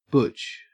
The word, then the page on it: butch: Australian English